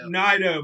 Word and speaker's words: Nidoh: Did I pronounce the name correctly? – Nidoh. – My mistake.